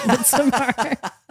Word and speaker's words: Midsommar: But, you know, we've watched interviews where that is how Ari Aster pronounces it. So we're going to be those pretentious cucks and pronounce it Midsommar.